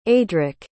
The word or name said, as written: aidric